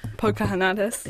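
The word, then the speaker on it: Poukahangatus: Say the name for us again. – Pocahontas. – There we go. Struggle with that pronunciation.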